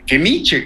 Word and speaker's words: Nietzsche: Y se pronuncia igual que, Nietzsche, que, el filósofo Nietzsche.